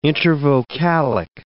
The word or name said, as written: intervocalic